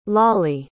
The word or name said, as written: Lali